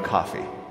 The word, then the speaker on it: Coffey: introduces a compelling character by the name of John Coffey, like the drink, only not spelled the same.